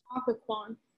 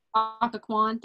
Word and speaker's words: Occoquan: Of those arrested, 97 of them spent time in either the, I'm gonna butcher it, Occoquan, I think that's Occoquan. – Occoquan, thank you, workhouse in Virginia or in the District of Columbia jail.